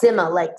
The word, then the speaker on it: Sima: My name is a tricky one. It's pronounced Sima, Sima down.